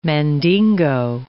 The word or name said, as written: Mandingo